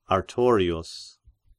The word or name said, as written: Artorius